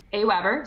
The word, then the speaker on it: AWeber: AWeber, so you did pronounce it correctly.